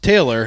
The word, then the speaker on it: Talor: Is it T-A-L-O-R? – T-A-L-O-R. Pronounced Taylor, and then my last name is Gooch.